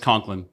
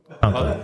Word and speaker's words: Conklin: Do you like the emphasis on the K, Conklin, or on the Conklin? – No, I think just Conklin. Conklin. Did I emphasize the K? – Well, that sounds all right to me.